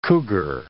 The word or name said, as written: cougar